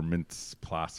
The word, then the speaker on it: Mintz-Plasse: But I do appreciate I think Christopher Mintz-Plasse, however you say his name, McLovin's.